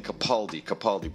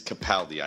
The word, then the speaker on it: Capaldi: Capaldi is incredible, and it is pronounced Capaldi. We've been saying Capaldi, but it is Capaldi. I have confirmed this with the man himself, who was a lovely gentleman.